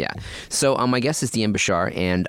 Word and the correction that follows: Bachar: Bachar. I always pronounce the C.